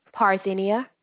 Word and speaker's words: Parthenia: Parthenia.